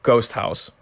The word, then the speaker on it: GHOSTHAUS: Something I did want to point out is that if you're looking up GHOSTHAUS, it is all one word, and house is spelled differently. It's spelled H-A-U-S, or the German way of spelling house.